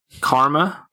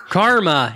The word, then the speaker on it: karma: Like you said, comma. – Karma. – Karma. Yes. Okay. That's the one. My bad. – Karma. That's how we pronounce it, which is different to comma, because comma is a punctuation mark.